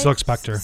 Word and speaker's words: Spectre: Spectra, is that how you say it? – Silk Spectre. – Spectre. – Okay.